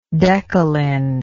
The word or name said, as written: decalin